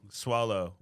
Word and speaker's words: Soileau: And Soileau. – Soileau. Yeah. But it's spelled different. – It's spelled weird. – It's S-W-A-A-A-A-L.